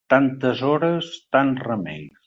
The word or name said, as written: remeis